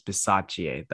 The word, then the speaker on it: Bisaccia: I just looked up his name. It's Bisaccia. That's how you say his name. Rich Bisaccia.